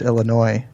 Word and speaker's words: Illinois: Well, but they're Illinois is pronounced, it's not Illinois, it's Illinois, and Myers isn't Myers, it's Meyer. – So well, I know. – The S is silent.